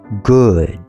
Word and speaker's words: good: 那它的正确念法是 OO 的发音是念 U，就像注音符号 U 的一样，所以是 good。